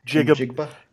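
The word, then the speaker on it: Njigba: Jigba.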